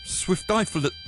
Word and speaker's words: Swiftiphyllis: Oh, how do you say this? Swift... That doesn't sound right. Swift, so it's got to be Swifty, right? And then they've added Phyllis. Swiftiphyllis. Swiftiphyllis.